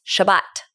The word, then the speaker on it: Shabbat: But actually, it's Shabbat. That's how you say it, Shabbat.